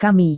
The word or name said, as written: kami